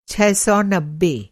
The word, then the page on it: छह सौ नब्बे: ६९०